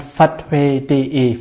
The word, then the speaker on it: Phathue: Also www und dann fatui.de. Fatui wird P-H-A-T-H-U-E geschrieben. Also wie Fatui ausgesprochen? P-H-A-T-H-U-E.